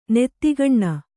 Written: ನತಕೋನ

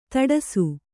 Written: ಧಾಡಸೀ

